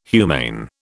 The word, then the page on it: humane: British English